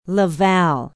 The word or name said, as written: la valle